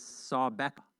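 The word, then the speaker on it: Sawbeck (סַבְּכָא): So I went to good old Strong's, and apparently the way you pronounce this is Sawbeck.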